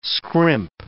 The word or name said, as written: scrimp